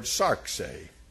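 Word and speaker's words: sarx (σάρξ): it's from the Greek word sarxe. It is sigma, alpha, rho, xi. And you pronounce it sarx.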